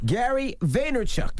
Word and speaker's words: Gary Vaynerchuk: That's right, my favorite corporate thug motivator, Gary Vaynerchuk. Did I say that right? – You did, my man.